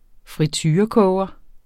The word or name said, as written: friturekoger